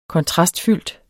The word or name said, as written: kontrastfuld